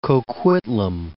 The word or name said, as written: Port Coquitlam